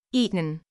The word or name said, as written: eaten